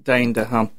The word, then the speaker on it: Dane DeHaan: Dane DeHaan.